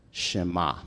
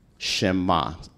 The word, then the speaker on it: Shema: The Hebrew word for listen, Israel, is the Hebrew word Shema. Shema. It's spelled S-H-E-M-A.